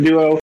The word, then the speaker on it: Duexo: I think the anniversary is like 3 years now that I've been Duo. Honestly, I've always liked the name Duo, and I wanted to spell it D-U-O.